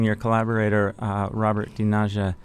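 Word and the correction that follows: Del Naja: Is that saying it right, DiNaja? – No, Del Naya. He'll kill me if I don't tell you.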